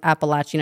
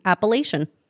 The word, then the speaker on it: Appalachian: I saw a girl, she was talking about being from the area, and she said Appalachian, and I was like, what? I've just learned something, because my entire life I pronounced it as Appalachian.